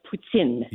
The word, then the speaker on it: poutine: It's actually pronounced poutine. – Yeah. – Oh, poutine. – It's almost like like poo plus tin can.